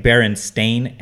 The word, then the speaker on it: Berenstain: But I guess the appropriate way to spell it, I guess, is B-E-R-E-N-S, like Berenstain, S-T-A-I-N, or whatever. So it's Berenstain Bears or whatever.